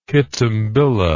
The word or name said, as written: kitembilla